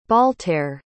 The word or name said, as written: bhaltair